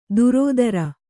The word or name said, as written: ದುರೋದರ